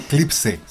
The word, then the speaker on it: Clipse: Después escuchábamos a Clips. – ¿Clips? ¿Como los clips de las hojas? – Claro. O si no, Clipse. Se escribe Clipse. – Como Eclipse.